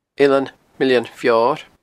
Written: Eilean Mill an Feoir

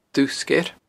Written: Dubh Sgeir

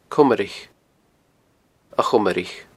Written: Comraich

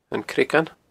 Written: Creagan

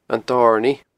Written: Dornaidh

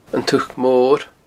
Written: uchd mor

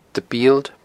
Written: Bield

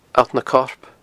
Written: Allt nan Corp

